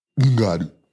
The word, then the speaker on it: Ngannou: Ngannou.